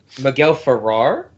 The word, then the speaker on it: Miguel Ferrer: Miguel Ferrer? – Ferrer, yes, excuse me. – Okay, yeah. – My bad, my mispronunciation.